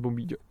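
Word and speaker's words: bombilla: Jako Češi to vyslovo bombila, ale jako má se to vyslovovat bombíža.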